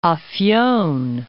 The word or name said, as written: Afyon